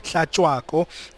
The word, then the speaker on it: Hlatshwako: And I was walking around the stands just now, and I had the opportunity to meet Mancoba Hlatshwako.